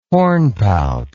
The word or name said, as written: hornpout